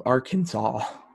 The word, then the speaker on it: Arkansas: and the accent was to be on the first and last syllable. Like, they detailed all of this out. So, you know, which eventually led to the pronunciation of Arkansas.